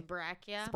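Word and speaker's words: Sbraccia: I'm going to say um, Sprachia. It's S B R A C C I A. – Brachia. Brachia.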